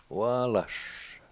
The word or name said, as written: waalash